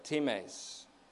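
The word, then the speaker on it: times (τιμῆς): Since, says the psalmist, the price, same Greek word, times, T I M, long E S, the price of redeeming him is too costly.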